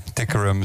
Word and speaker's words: Tikaram: Ja, waar leg je de klemtoon? – Tikaram, zo werd het toen al op de radio gezegd. Dus vroeger.